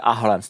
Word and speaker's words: Ahlem: No a tak je prostě poslali jeho a jeho otce do tábora e, blízko Hanouru, který, se, eh, který je u Álemu, tam se tak vyslovuje Ahlem, to píše Álem.